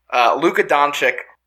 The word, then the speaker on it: Luka Doncic: Luka Doncic, I feel like I didn't say it right, but whatever.